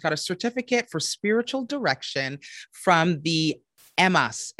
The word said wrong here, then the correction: Emmaus: Did I say that right? – Uh, MAS the Emmaus. I love that.